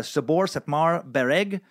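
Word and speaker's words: Szabolcs-Szatmár-Bereg: I think it's pronounced Sabor Sepmar Bereg. I was on this some fucking crazy Hungarian pronunciation guide for way too long. But it's spelled like Shblak Shmashbrela. That's how it's spelled, basically.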